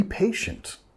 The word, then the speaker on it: patient: Nota que vamos a enfatizar la primera sílaba, patient. También tenemos ese, la T, I, sh, sh, sh. Patient.